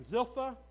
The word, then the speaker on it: Zilpah: Zilpah.